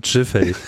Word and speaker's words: Giffey: Und it's pronounced Giffey.